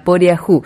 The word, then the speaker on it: Poriajú: Así que era Poriajú la pronunciación de esa canción que escuchábamos más temprano,